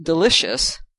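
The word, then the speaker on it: del.icio.us: it's pronounced delicious, but it's spelled D-E-L. .ICIO.US.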